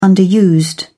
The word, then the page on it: underused: British English